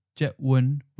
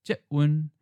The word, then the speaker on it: jetwen: It should be pronounced jetwen with a fourth and then seventh tone because the first syllable jet is an eighth tone. That should change to a fourth tone. In other words, from jet to jet.